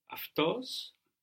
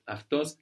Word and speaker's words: αυτός: And we mentioned as well before that αυτή, αυτός is spelled with AU, and that this combination of AU, or the Greek letters that look like A and U, is giving us AF.